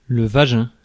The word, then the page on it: cunt: Australian English